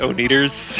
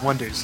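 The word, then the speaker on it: Oneders: They kept pronouncing it O'Neaters. – Yeah. – And then they announce him on stage. They go, the O'Neaters. And he goes, wait, that's O'Netters. – Oh, they're explaining to it to begin with. It's on the paper. It's about Wonders. And he's like, no, it's pronounced the Wonders. And he says, yeah. It looks like the O'Neaters. Yes. But it's the Wonders.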